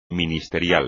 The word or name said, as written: ministerial